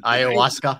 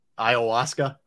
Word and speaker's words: ayahuasca: Ayahuasca. Name. Yeah. – What is it? – Ayahuasca.